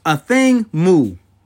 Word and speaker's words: Athing Mu: So, my Black History icon I want to give today, hopefully I get her name correct, and it's pronounced Athing Mu. It's spelled A-T-H-I-N-G-M-U. Athing Mu. I had to make sure I was saying it right,